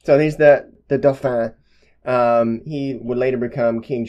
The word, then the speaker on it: Dauphin: They were telling her to go to the Dauphin, which I think, again, is how you pronounce that.